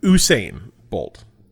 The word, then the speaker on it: Usain Bolt: How do you say this guy's name? Usain Bolt? Is that how it is pronounced? – Usain Bolt, right? – Yes.